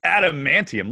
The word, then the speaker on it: Adamantium: Adamantium come out of your hands. And then we can talk show about me this Wolverine you keep speaking of. I want to see it. Adamantium.